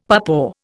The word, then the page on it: papaw: Indian